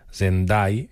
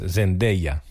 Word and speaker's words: Zendaya: Vaig veure un vídeo un dia que ella ho explicava, perquè es veu que la resta del món li diuen Zendai, però Dai és com morir, no? Dai, i s'escriu Day com dia, i és Zendaya.